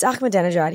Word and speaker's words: Ahmadinejad: And I mispronounced his name, and I remember him being like, "It's Ahmadinejad.